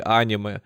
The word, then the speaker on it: аниме: в котором мы сейчас ее знаем и называем аниме, а или аниме угу. – э, с ударением, да, на первый слог.